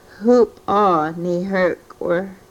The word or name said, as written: Hupoo ni hrkwrh